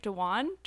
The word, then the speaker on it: Dewan: So in case you live under a rock, Channing Tatum and Jenna Dewan Tatum, that's how you say her name, right? Dewan? – How's it spelled? – D-E-W-A-N. – Yeah. I mean, that's how I'd say it.